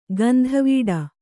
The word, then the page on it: ಗಂದವುಡಿ: gandavuḍi